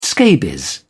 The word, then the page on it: scabies: British English